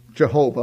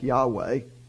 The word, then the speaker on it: Yahweh: his name Jehovah, or probably more correctly pronounced something like Yahweh, when God revealed that name, he said, he gave us its meaning, I am.